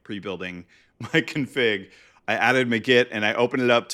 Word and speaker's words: Magit: there's this package called Magit or Magit. Like everyone debates how to pronounce it, but it's like kind of like magic, but with the T at the end for Git.